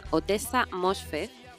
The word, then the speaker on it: Ottessa Moshfegh: Se llama Otessa Mosfer y probablemente nuestras escuchantes ya la conozcan y de hecho muchas de ellas la habrán leído porque esta novela tiene ya unos añitos. – ¿Cómo se escribe? A ver, Otesa doble T. Con doble T, doble S y luego el apellido lleva dos H traicioneras por ahí. – Que es Mosh.